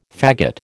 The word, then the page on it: fagot: American English